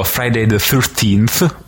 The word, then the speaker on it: Friday the 13th: ovvero Friday the 13th, credo si pronunci, sì. Giusto Luigi? Sì. Esatto.